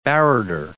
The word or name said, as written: barrator